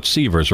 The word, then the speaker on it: Sivers: It's spelled S-I-V-E-R-S. We're going to go with Sievers, right, Kerr? – I believe that's the pronunciation.